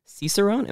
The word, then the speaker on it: Cicerone: And, you know, along with owning ABC Beer Co., you're also a Cicerone. Am I saying that right? – You got it.